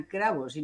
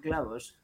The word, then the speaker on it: cravos: Porque además lo he dicho mal en portugués, serían cravos y no clavos. – Con R, ¿no?